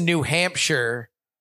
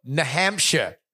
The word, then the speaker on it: New Hampshire: that New Hampshire says New Hampshire like Australians say good day. – Hampshire? – No, they go New Hampshire. Like it's N apostrophe Hampshire.